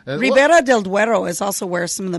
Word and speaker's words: Ribera del Duero: Ribera del Duero. Your pronunciation is not bad at all.